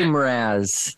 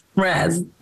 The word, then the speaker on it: Mraz: yeah, I I say Mraz. – Mraz. Okay. Yeah. So, I try to make it one syllable.